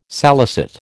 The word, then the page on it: salicet: American English